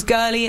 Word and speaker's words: Girli: My name is Girly. It's spelled G-I-R-L-I, not G-U-R-L-I, like the IKEA pillow.